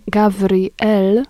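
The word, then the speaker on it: Gavriel (גבריאל): Ono po hebrajsku brzmi Gavriel, a oznacza moc Boża, wojownik Boży.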